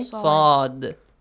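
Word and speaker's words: Saad (ص): So how do you say the letter saad? Who can say it? – Saad. – You put your tongue back of your teeth and okay. make a upgoing frequency sound.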